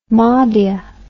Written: madia

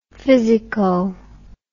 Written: physico

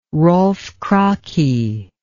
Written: Rolf Kraki

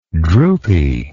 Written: droopiness

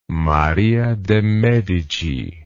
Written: Maria de Medici

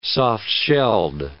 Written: soft-shelled

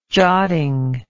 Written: jotting

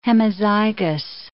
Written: hemizygous

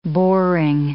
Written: boring

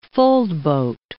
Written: foldboat

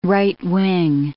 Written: right-wing

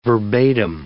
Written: verbatim

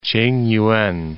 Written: Tsingyuan

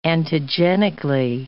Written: antigenically

